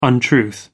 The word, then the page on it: untruth: American English